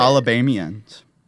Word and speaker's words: Alabamians: Alabamans? Is it Bamian? What is it? – Alabamians. Alabamians.